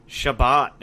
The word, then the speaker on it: Chabot: You know, uh, is it Cabot or Chabot? How do you say that name? Shabbat, dude. Shabbat.